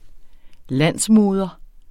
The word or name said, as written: landsmoder